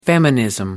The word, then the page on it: feminism: American English